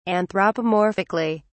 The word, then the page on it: anthropomorphically: British English